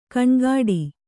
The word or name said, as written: ಘನಘಟ್ಟಿ